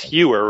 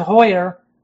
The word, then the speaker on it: Hauer: Well, no, in Germany they pronounce it Hoyer. – Oh, okay. – So, I mean, but, I just in America they pronounce it Hewer, you know.